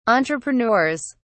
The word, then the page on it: entrepreneurs: British English